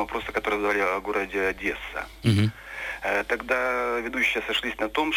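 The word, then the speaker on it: Одеса: По-украински произносится без удвоенного «с», просто Одесса.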